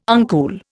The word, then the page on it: uncool: Indian